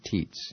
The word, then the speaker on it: teats: And that's spelled T-E-A-T-S. No, you don't pronounce it teats. You do? All right. As useless as teats on a bull.